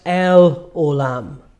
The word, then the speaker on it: El Olam: In Hebrew, it's pronounced El Olam, God eternal.